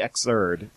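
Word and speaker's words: Xrd: So, you'll have to tell me if I'm saying this wrong. Guilty Gear X... – You're already saying it wrong. – Guilty Gear X-erd. – No, it is actually X-erd. – Sign. – You're quite right.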